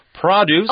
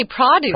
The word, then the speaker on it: produce: Produce. – Oh, you say produce. – Produce or produce. – Oh, I say produce. – Produce. – Okay, different pronunciations.